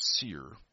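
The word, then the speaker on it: sear: Simple a seer, which, by the way, is pronounced seer, modification would have permitted one entire magazine to shoot all at once with no select fire.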